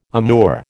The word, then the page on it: amour: American English